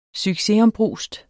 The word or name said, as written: succesombrust